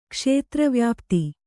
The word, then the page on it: ಕ್ಷೇತ್ರವ್ಯಾಪ್ತಿ: kṣētravyāpti